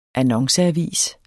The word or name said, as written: annonceavis